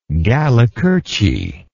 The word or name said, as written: Gallicurci